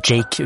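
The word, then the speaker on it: JVKE: Han kaller seg bare Jake, og Ann skrives med V, J-V-K-E, men Jake uttales det som.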